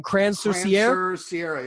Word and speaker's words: Crans-sur-Sierre: At Crans, how do you say it again? Crans-sur-Sierre, yeah.